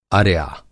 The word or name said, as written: अर्या